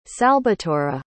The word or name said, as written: salbatora